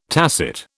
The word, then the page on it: tacit: British English